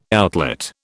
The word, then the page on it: outlet: British English